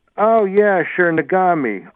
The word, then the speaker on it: Nagami: Okay, so, we had a caller call in and say the variety you want that will taste good is Nagami, and I think that's spelled N-A-G-A-M-I. – Oh, M-A-G. – N as in Nancy. N-A-G, A-M-I.